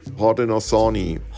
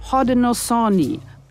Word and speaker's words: Haudenosaunee: Heute sagt man meist, wie spricht man das aus, halbwegs korrekt? – Haudenosaunee. – Haudenosaunee, so nennen sie sich selbst.